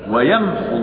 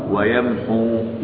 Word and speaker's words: ويمح: ويمحو الله الباطل ويمحو الله الباطل. كلمة ويمح هذه ليست فيها واو مرسومة فلا تنطق لا وصلا ولا وقفا حتى إذا وقف حتى إذا وقف. ويمح فقط لا أقول ويمحو لا لا لا لا.